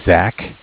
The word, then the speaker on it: Zach: And then my name is Zach, spelled Z-A-C-H. – Okay. – And I'm from Bank of Cardiff. Cardiff is spelled C-A-R-D-I-F-F.